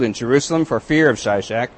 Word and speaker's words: Shishak: It says, did Pharaoh Shishak attack Jerusalem? And it's about this time when Shishak, as it says in the Bible, that's the way the Hebrews pronounced his name, came and attacked and captured the fortified cities of Israel and went right up to Jerusalem.